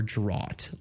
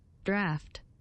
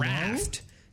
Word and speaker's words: draught: Drought or draught. Let's see. It's definitely draught. – Draft. – Hello? Draft. That is spelled, for people at home, it is D-R-A-U-G-H-T.